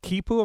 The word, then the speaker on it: quipu: Now, they had this, it's called quipu, am I saying that right? – I think so, yeah.